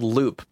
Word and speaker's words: Looop: So there's a tool called Loop. It's spelled with 3 O's, L-O-O-O-P.